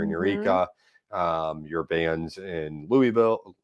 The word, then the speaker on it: Louisville: It's Louisville. You almost have to make it two syllables. I say Louisville because a lot of people don't know what I'm talking about when I give it the Kentucky twang.